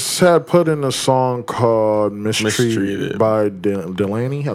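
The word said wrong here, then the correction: DaniLeigh: Danny Lay? – Danny Lay, my fault.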